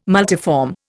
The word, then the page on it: multiform: Indian